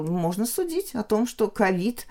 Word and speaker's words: ковид: Вот я хотела потом предложить, ну, правда, не очень приятная сама сущность, которая так обозначена, но порассуждать о новом слове ковид и ковид. Вот ковид, англоязычный вариант ковид, угу. да, а мы стали все-таки произносить ковид. Сейчас пока еще нет отражения угу. в словарях, как это правильно. И в речь встречается то и другое.